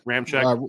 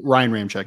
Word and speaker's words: Ramczyk: Ramczyk. – Ryan Ramczyk.